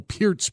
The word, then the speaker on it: Peart: I mean, we got news on Friday of Neil Peart's passing, and that is how it's pronounced, kids. That's how he pronounced it, around 3:30.